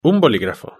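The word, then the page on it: un boligrafo: Spanish (Spain)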